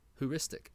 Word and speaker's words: heuristic: We're going to look up, first off, heuristic is how you say it. So I actually said it decently well. Not great, but well.